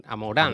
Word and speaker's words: Amouranth: a La Murad, es que no, lo digo mal, Amor, Amoranz.